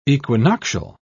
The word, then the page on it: equinoctial: British English